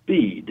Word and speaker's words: Beda: It's Beda, isn't it? – Yeah, in English, it's Bede. It was Beda is Latin for Bede, and Bede is an old Irish name.